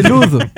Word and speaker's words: Loathe: Et donc le groupe, c'est Los, je ne sais pas comment on le prononce, L-O-A-T-H-E, Los, okay. Je pense. Le titre, c'est Gord. – Ok. – Ce qui veut dire breakdown. – Ah, c'est Loathe. L-O-A-T-H-E, Loathe.